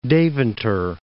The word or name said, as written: Deventer